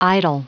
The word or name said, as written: idyl